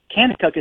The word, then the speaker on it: Kanakuk: Kanakuk is spelled K-A-N-A-K-U-K. Kanakuk. Everything with a K there.